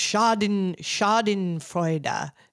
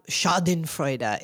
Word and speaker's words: Schadenfreude: It's Schadenfreude.